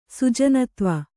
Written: ಸುಜನತ್ವ

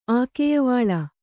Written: ಆಕೆಯವಾಳ

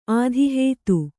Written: ಆಧಿಹೇತು

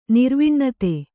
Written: ನಿರ್ವಿಣ್ಣತೆ

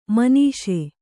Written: ಮಾನಿಸ

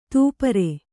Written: ತೂಪರೆ

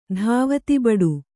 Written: ಧಾವತಿಬಡು

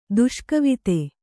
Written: ದುಷ್ಕವಿತೆ

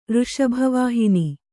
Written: ಋಷಭವಾಹಿನಿ

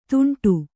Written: ದುಂಡು

